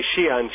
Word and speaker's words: Xi'an: which is modern day Xi'an. Xi'an is spelled X-I-apostrophe-A-N. The X is pronounced as Sh in Chinese.